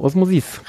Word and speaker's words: Osmosis: Genau, Osmosis, richtig. – Quatschig betonen letzte. Und dann irgendwie die Vokale verstümmeln und schon hast du Französisch. – Genau, auf die letzte Dings.